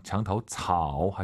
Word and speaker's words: 墙头草: 삼성이 마지막 글자지만 웬만하면 올리지 마세요. 장타오 차오 하지 마시고 토가 아니라 오유는 어예요. 장타오 차오, 장타오 차오 이렇게 빠르게 발음하시면 좋을 것 같아요.